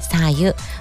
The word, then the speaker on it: 白湯: さ湯もしくはこれ白湯と読むのが一般的です